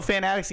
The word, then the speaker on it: Fanatics: How did you say that? Fanatics? – Fanatics. That's the company. – Fanatics. – Yeah. – It's not Fanatics? – Well, it's two words. – Is it like that double entendre type of thing? Yeah. Oh, Fanatics, Fanatics. Okay, I thought you were just putting emphasis on the wrong part of the word. – Fanatics. – Fanatics. Okay, I get it now. – Yeah. It's like, yeah, it's a play on words.